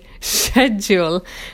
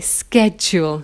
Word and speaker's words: schedule: So obviously, we don't say shul, so we shouldn't say schedule. We should say schedule, apparently.